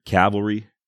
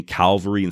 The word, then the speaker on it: cavalry: By the way, people have emailed me about how I pronounce the word cavalry. I do know it's C-A-V-A-L-R-Y. I know Calvary is where Jesus was crucified. Just the way I pronounce it, sometimes it sounds like I'm saying Calvary instead of cavalry.